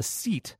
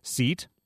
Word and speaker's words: cete: A group of badgers is called a seet, C-E-T-E, pronounced apparently seet.